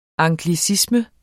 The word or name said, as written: anglicisme